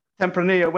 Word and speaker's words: Tempranillo: I always say Tempranillo. But how do you pronounce it? – Tempranillo. When it's two Tempranillo. L's, yes. It's pronounced like a Y.